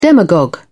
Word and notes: demagogue: British English

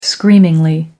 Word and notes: screamingly: American English

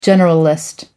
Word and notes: generalist: American English

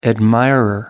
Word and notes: admirer: American English

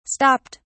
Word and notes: stopped: British English

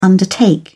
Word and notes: undertake: British English

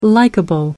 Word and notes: likeable: American English

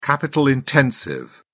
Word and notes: capital intensive: British English

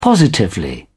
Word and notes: positively: British English

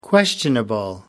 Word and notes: questionable: American English

